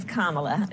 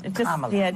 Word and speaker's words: Kamala: It's Kamala. – Kamala. – Just think of like a comma and add a la.